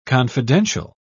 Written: confidential

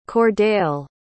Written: kordale

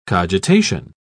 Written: cogitation

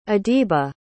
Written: adiba

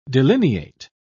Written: delineate

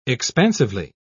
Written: expansively

